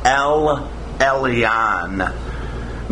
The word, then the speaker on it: El Elyon: The priest, the king, added an extra L, which in Hebrew made the pronunciation El Elyon, making him the God of all gods.